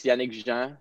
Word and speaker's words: Yannick Jean: Yeah, well in French it's Yannick Jean, so okay. I guess you could say like Yannick Jean, but it's a nice try. It's pretty good. It's tough to say.